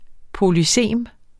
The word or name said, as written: polysem